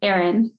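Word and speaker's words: Aaron: There's that whole, well, he's Erin and he's A-A-R-O-N. – Yeah. – You say your name. How do you say your name? – Erin.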